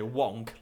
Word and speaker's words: wank: But obviously, wank. Americans say wonk, like yeah. Wonk, isn't it? Wank.